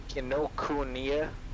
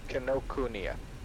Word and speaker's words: Kinokuniya: Kinokuniya? – Do I know? – You got it. – Kinokuniya bookstores.